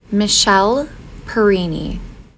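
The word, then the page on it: Michelle Perini: English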